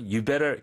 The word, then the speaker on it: you'd better: 아, 근데 실제 발음할 때는 you'd better. 거의 D가 안 들리죠? – 네. – 그냥 you better, 그냥 you better처럼 들리긴 하는데, when you write, you should write you'd better.